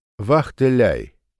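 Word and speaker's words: Wachtelei: «Вахтеляй! Вахтеляй!» — говорит он, делая ударение на последнем слоге, и выжидательно смотрит на нее, поймет она шутку или нет.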